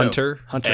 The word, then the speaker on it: Hunter x Hunter: Hunter. Hunter X, X Hunter. Hunter. You don't pronounce the X, but the X is in there. It's Hunter Hunter.